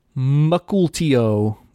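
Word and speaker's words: Mukilteo: from Chris Rollins, he says, because I tweeted for questions, he says, this reminds me to tell you that it's pronounced Mukultio. Mukultio? I don't know. Mukultio, Washington. He says he heard it on the podcast from a while back as he was getting caught up.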